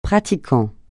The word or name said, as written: pratiquant